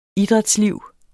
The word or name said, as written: idrætsliv